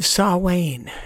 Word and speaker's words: Samhain: The Feast of Samhain.